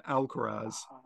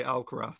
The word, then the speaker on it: Alcaraz: The only reason why I know how to pronounce that was because of Wimbledon with Alcaraz, because I was pronouncing it Alcaraz. And then I heard the commentator who have the pronunciation sheets say Alcaraz. I was like, oh, okay, so the Z is pronounced as a th.